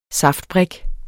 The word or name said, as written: saftbrik